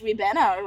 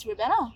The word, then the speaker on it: Ribena: And then she asked like another classmate, like another random classmate, she just went like, how do you pronounce this drink? And then we said, it's Ribena, right? And then we looked at them like from the back, we were like, just not and say yes. We like mouthed it to them. And then they're like, yeah, that drink is Ribena.